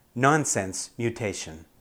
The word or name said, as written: nonsense mutation